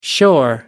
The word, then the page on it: shore: American English